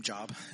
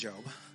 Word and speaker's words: Job: I know some people say his name Job. His name is Job.